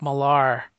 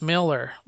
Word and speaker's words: Millar: Well, for the longest time, I could not say Mark Miller because I would say Mark Millar. And it's pronounced Miller, like he said that.